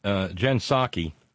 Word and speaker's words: Psaki: Yeah, because Jen Psaki, you don't pronounce the P, P-S-A-K-I.